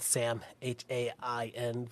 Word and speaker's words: Samhain: We know, we have fucking Samhain, we have, or, or Samhain, depending, you know, but it's spelled Sam, H A I N, but it's Samhain, but you know what I mean. You know, the M is silent.